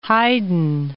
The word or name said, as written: Haydn